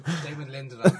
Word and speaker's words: Damon Lindelof: Say Damon Lindelof. – Damon Lindelof. Damn it! That's actually the correct pronunciation of his name.